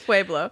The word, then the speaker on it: Pueblo: it's Pueblo, Colorado.